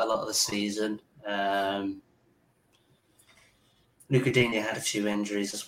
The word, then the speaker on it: Digne: Abdoulaye Doucouré, Ben Godfrey, or Luca Digne? And beautifully pronounced, if I say so myself. – Yeah, that's perfect.